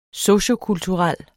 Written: sociokulturel

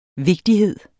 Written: vigtighed

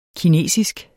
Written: kinesisk

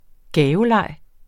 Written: gaveleg

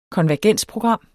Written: konvergensprogram

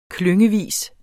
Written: klyngevis

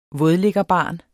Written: vådliggerbarn

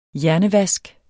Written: hjernevask

